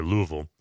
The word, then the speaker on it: Louisville: For those of you who don't live in America, Louisville, it's exactly 1.25 syllables, Louisville. Louisville, Kentucky.